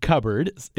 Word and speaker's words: cupboard: The extra letter in the word cupboard cupboard is spelled cupboard, right? – Right. – And I guess we don't really say the P, so I guess the extra letter is a P.